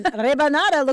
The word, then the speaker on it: rebanada: Rebanada. – Rebanada. – I love it. – You can roll your R.